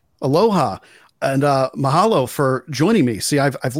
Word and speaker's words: mahalo: Yeah, it's mahalo. It's like your vowels are pronounced. Your A is ah. Okay. You know, and so, and your O is O. So mahalo is how you say it.